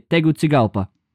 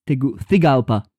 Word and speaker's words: Tegucigalpa: How do you pronounce it, my dear host? Because I say Tegucigalpa. – That's how I would say it as well.